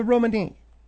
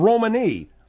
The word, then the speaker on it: Romani: They're not called Roman, they're called Romani.